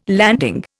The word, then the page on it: landing: Indian